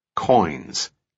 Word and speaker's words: quoins: Sam said, "Selma, the word in the last line, coins." – "It's spelled Q-U-O-I-N-S. – "You're sure that's it?" – "We're sure. I triple-checked it myself, then had Pete and Wendy do the same. Why?" – "Coin is an architectural term.